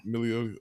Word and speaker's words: Milioti: I think it's Milioti. – Milioti, okay.